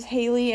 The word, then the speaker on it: Hailie: So, he's liked Eminem since he was a kid, and my name is Haley, and it's spelled H-A-I-L-I-E, just like Haley Jade, his daughter, if you did not get that reference.